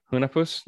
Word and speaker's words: Hunahpu's: It's the Double Barrel Hunapus. – Okay. That's what we'll call it. – H-U-N-A-H-P-U-S. – Yeah, Hunapus.